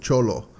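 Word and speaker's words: Xolo: Yeah, it's pronounced Cholo. – Oh, is it? – Yeah. – Is it Cholo? Is it? Yes, no, it's Cholo.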